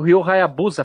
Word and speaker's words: Ryo Hayabusa: é muito parecido com o Ryo Hayabusa, falei certo? – Sim, sim.